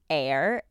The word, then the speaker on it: Aire: and it's Aire, A I R E, pronounced like air, because a lot of people were like, is it pronounced airy?